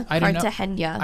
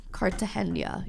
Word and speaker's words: Cartagena: Is that how it's pronounced? – Is that how they pronounce it? – Yeah, Cartagena. – I've never said the word out loud. I've only looked at it. – Cartagena, yeah. – Because it's with a G.